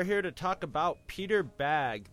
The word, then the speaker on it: Bagge: Yes, true believers, that is how you pronounce it, even though it's spelled B-A-G-G-E. – I was just going to say, because I've heard people say Baggy too, but I like Peter Bagge because it's P.